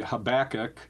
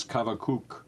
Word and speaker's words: Habakkuk: Well, I think kind of a sort of a stock way, Habakkuk. – Yeah. – But if you look at it in Hebrew, it's Habakkuk.